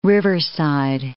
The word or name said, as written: riverside